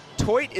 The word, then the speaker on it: Theut: Toit is spelled T-H-E-U-T, Dylan Toit.